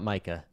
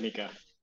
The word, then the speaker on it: MiCA: And, um, and you guys say Mika, not Micah. – Excuse me. – I don't know. Maybe Patty will correct me, but what is I the say- right pronunciation? – Give it to her.